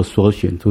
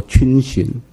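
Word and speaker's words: 逡巡: 还有一个字，我也是读了很多年都读错的，就是缩寻，那不读作缩寻，读作逡寻，就是有点徘徊不前的样子的那个字。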